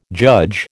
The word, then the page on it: judge: American English